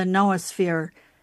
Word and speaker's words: noosphere: And in that book, The Story of the Noosphere, and by the way, for listeners, noosphere is spelled N-O-O-S-P-H-E-R-E, noosphere with an N, not with a K-N-O-W, but noosphere.